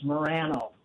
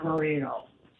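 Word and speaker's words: Moreno: If you talk to anybody that has any Hispanic background and you look at the name, they'll say it's pronounced Morano, not Marino.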